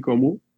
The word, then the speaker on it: Komu: A-L-E-K-S-I. Puis Como, ça se prononce, ça s'écrit K-O-M-U. Mais le O, eux autres, il est comme fermé un peu.